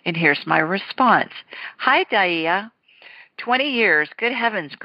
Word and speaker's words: Dyea: And this one comes from, I believe she pronounces her name Dyea, D-Y-E-A.